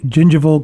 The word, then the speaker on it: gingival: Connective tissue grafts, free gingival grafts, and pedicle grafts. Did I pronounce them all correctly? – Yes, okay. You did.